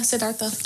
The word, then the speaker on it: Siddhartha: I was thinking about when they talked about Buddhism and they said that when Siddhartha, is that how you say it? – Yeah, Siddhartha.